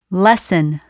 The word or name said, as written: lesson